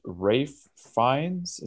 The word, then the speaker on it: Fiennes: And the movie stars Colin Farrell, Brendan Gleeson, and Ralph Fiennes.